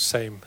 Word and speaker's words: Seem: He actually spelled his name Seem, but I think it was Same how you pronounce it.